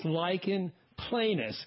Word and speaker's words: lichen planus: It's lichen planus, and it doesn't quite look like what it sounds like, but this is an inflammatory condition.